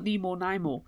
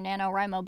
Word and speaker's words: NaNoWriMo: I can never say it right. I always call it Nemo Naimo. – You do. You always make up something that is not anywhere near NaNoWriMo, but it means National Novel Writing Month, just in case everyone doesn't know that already. So... your Niner Namo is not ever what it's supposed to be.